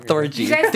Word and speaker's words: Thorgy: Thorgy. – She looks like the girl from Orphan Black. – Did I say it wrong? – Thorgy. Orgy.